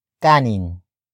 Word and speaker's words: kanin: And that is kanin. Kanin. Kanin. Kanin is spelled as K-A-N-I-N.